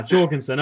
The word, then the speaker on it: Jorgensen: Check out, is it Jorgensen, the libertarian presidential candidate? Check her out. Your official endorsement was recent. – Yes, slightly less European pronunciation on that. – Ah, Jorgensen. Okay, I can't help myself.